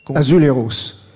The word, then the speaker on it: azulejos: Azuleros. Azuleros ou Azulejos, euh, euh, c'est comme on veut, mais en général on dit plutôt euh, à l'espagnol, la prononciation à l'espagnol, Azulero ou Azuleros.